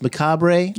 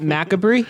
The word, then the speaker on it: Macabre: He'd been doing movies in Hollywood for many decades, and he opened his directing career with one called Macabre. I think that came out in 1958. – I believe it's pronounced Macabre. – Ooh, you're so right. – Not Macabre? – Macabre.